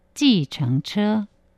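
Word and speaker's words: 计程车: 그래서 계정, 또 수레차자를 써서 계정차라고 하는데 발음은 지정차.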